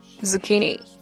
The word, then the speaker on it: zucchini: Zucchini. Okay, CH pronounced like k. Zucchini.